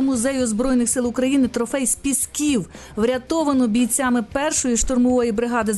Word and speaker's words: Пісків: А знаєш, я звернув увагу, що ти правильно сказала «з пісків». Тому що так. У нас наголос дуже часто журналісти ставлять інакший.